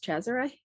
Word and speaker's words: Cesare: Well, it's an Italian name, and as far as I'm aware, it's pronounced Cesare.